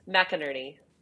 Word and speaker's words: McInerney: McInerney.